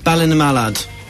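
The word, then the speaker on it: Ballinamallard: And how do you pronounce it? – Ballina Mallard. – Ballina Mallard? – Yeah, it's Northern Ireland.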